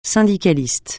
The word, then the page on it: syndicaliste: francais